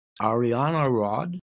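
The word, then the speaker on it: Arianrhod: In Celtic mythology, the tiger goddess Ariana Rod embodied fertility and magic. That's A-R-I-A-N-R-H-O-D on the pronunciation.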